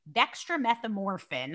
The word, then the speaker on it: dextromethorphan: One published in 2007 that evaluated buckwheat buckwheat honey, and found it to be superior to no treatment and equal to honey-flavored dextromethorphan.